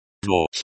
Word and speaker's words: дочь: The word дочь is feminine, so it changes form based on the case. Pronunciation tips. Дочь is pronounced like doc with a slight emphasis on the first syllable.